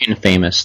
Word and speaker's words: Infamous: That's pronounced Infamous, though. – Infamous.